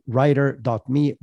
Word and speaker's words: Rytr: And the other is called writer.me. Writer is spelled R-Y-T-R.